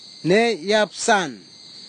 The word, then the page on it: neiapsan: kwamera; Tanna